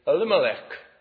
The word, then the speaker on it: Elimelech: Well, that's the Welsh way of saying it. We tend to emphasize the penultimate syllable, don't we? We say Gwaincai Girwin and we say Penryn Daidraith. Americans say it very neatly. They say Elimelech.